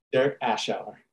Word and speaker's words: Derek Ashauer: Derek Ashauer.